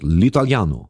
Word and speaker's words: L'Italiano: L'Italiano.